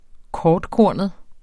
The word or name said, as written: kortkornet